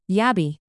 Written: yabby

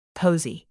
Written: posy

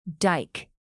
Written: dike

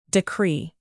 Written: decree

